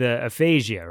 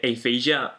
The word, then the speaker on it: Aphasia: I know that Americans say aphasia, but in fact, I say aphasia.